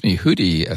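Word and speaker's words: Huddie: That's Hootie, I think is how it's generally pronounced.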